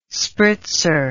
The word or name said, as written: spritzer